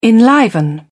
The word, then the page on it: enliven: British English